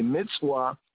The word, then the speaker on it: mitzvah: The Hebrew word for commandment is mitzvah, according to the Afro-Semitic, Afro-Asiatic pronunciation. Mitzvah. Modern Hebrew will be said as mitzvah.